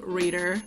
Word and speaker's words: ReadHer: It's called Reader. It's spelled R-E-A-D-H-E-R. And it's because it's a subscription book box specifically created for women.